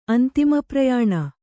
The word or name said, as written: ಅಂತಿಮಪ್ರಯಾಣ